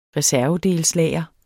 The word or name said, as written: reservedelslager